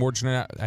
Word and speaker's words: Wojnarowski: Kyrie Irving, apparently, according to Woj, Adrian Wojnarowski, I never know how to say it. It's like trying to say Worcestershire sauce. How's his last name? Say it for me. – Wojnarowski. – Wojnarowski. I always want to put an R before the J, like Wojnarowski.